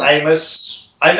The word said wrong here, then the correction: Omos: Omos. – Xavier Woods. Omos.